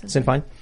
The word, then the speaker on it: Sinn Féin: You know, Irish Republicanism, where now the Republican Party is Sinn Féin. Is that how you pronounce it? – Yes. – Sinn Féin.